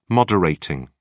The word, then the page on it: moderating: British English